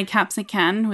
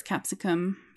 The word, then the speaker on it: capsicum: Yeah, like I sometimes say capsican with an N sound at the end, but it's capsicum.